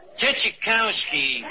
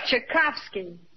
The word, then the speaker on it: Tchaikovsky: Chachakovich. And, uh, David uh, Puccini, batch. Tchaikovsky. – Archie, that's Tchaikovsky. So I mispronounced one of them.